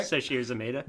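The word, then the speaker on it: Sasheer Zamata: Is that, did I say that? Did I almost say that right? – Shashir Zameda. – That's the one.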